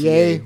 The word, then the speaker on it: Ye: I think it's Ye. It's pronounced Ye, but whatever.